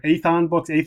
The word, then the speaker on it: Atheon: Let's talk real quick about, and I'm going to pronounce it wrong a couple times here, Atheon Books. – Oh, you got it. You got it right.